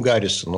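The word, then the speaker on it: Harrison: Президентом США становится Уильям Гаррисон, он же Харрисон. Харрисон, его по-разному произносят.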